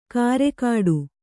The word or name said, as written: ಕರೆಕಾಡು